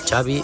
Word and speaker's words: Xavi: Xavi Hernández. – Xavi. – Yeah. So I heard it. Xavi like chocolate.